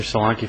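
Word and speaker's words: Solanki: Mr. Solanke for Appellant Todd Ashker.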